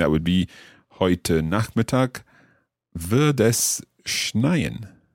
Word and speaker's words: wird: Wird. It's a kind of T sound at the end of that. Wird es schneien. – Yeah, but it's spelled with a D.